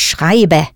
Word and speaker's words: schreibe: Richtig, ich schreibe. Pronounce that E ending for the ich person clearly.